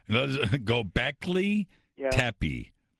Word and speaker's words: Göbekli Tepe: Göbekli Tepe. – No, Göbekli. Göbekli Tepe.